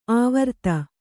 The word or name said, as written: ಆವರ್ತ